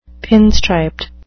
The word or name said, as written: pinstriped